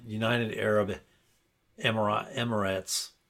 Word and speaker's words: United Arab Emirates: Next story in Farmageddon is from United Arab Emirates.